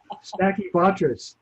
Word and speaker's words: Stachybotrys: I always say it wrong. What's wrong with me, man? What is it? Stinky butt ass? – Stachybotrys. – Stachybotrys. There you go.